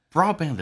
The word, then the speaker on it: broadband: Broadband 的 D 不发音，因为两个辅音，第一个 D 不发音，两个辅音中发后面一个叫 broadband.